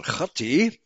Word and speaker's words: Khati: I should pronounce it Khati. That's the CH, the guttural CH of the Hebrew.